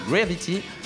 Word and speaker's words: Gravity: Cette semaine, tu nous as fait un petit coup de cœur sur le film Gravity.